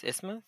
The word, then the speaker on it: isthmus: Okay, so we need to go over the is- isthmus, right? – Isthmus. – Yes. Like it rhymes with Christmas.